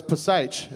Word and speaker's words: Psych: It's not the same show as Psych, is it? – Oh, that's how it's spelled. Huh? It's the P is silent.